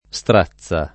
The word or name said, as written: strazza